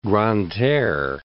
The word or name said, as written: Grande-Terre